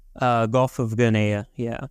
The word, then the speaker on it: Guinea: Guinea, yeah. Well, yeah, guinea pigs.